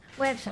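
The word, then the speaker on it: Güepsa: ¿Cómo se dice a los que nacieron en Huespa? Perdón, Huepsa. Websa. ¿Ah? Con diéresis.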